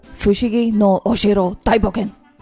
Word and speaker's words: Fushigi no Oshiro Daiboken: Fushigo. Nej, vi måste uttala det rätt. Också. Fushigi no Oshiro Daiboken.